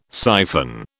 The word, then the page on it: siphon: British English